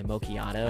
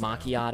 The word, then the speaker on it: macchiato: And this is just fun when I go to, like, you know, a Starbucks and I go get my macchiato. – I want Starbucks. – Macchiato. Now. It's macchiato. Sorry, I said it wrong.